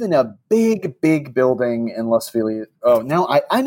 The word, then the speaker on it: Los Feliz: Los Feliz. I'm just going to say that because that's what people have said to me when I've said it other ways.